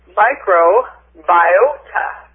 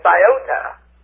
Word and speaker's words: microbiota: Okay, but let's look down here at microbiota. And there's another long O, it's biota. Even though if we say biology, we're going to say biota.